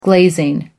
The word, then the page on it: glazing: American English